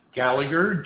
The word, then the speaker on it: Gallagher: Gallagher, G-A-L-L-A-G-H-E-R.